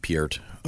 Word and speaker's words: Peart: And Neil, now it's, I don't know the exact, I've heard so many different ways to Peart. – Pronounce it. Peart.